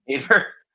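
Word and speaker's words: Nadir: Nader. I don't know the – N-A-D-I-R. I don't know the pronunciation of that Nader. – Word. – Nader. – Nader. Yes.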